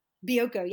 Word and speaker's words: Bioko: Bioko? – Bioko, yes.